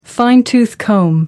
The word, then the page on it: fine-tooth comb: American English